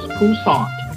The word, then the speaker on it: Poussaint: It's only two syllables, Poussaint. So it's French.